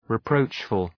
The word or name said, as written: reproachful